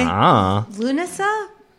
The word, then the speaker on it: Lughnasa: Dancing at Lugnasa City. No. Dancing at Lugnasa by the City Theater. Did I pronounce that right? Lugnasa? Anybody? – Lunasa? – Yeah. – It's got a G in it.